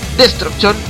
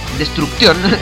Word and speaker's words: Destruction: Ya está confirmada la fecha en la página de Destruction, que es Destruction, tal cual deletreándolo, Destrucción, como podrían decir algunos, así, falta, leyendo mal, mal la pronunciación, por el país de Alemania.